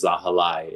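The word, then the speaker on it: Zahilay: It is Girmay, and last name is Zahalai. It rhymes with Girmay.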